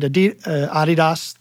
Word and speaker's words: Adidas: So I did Adidas, the proper pronunciation.